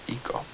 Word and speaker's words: Ico: Ico. Is it? I feel like that's independent fair title at the time. – That's how it's pronounced? – Yes. It's okay. Japanese. – Well, all right. – Ico.